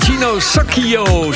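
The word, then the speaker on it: Gino Soccio: Het is eh, de Italiaan Gino Sacchio, zo spreek je het uit.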